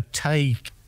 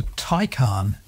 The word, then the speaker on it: Taycan: I might have said a Tay, but no, it's not that, is it? – No, it's a Taycan. – Well, T-A-Y-N.